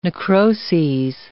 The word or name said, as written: necroses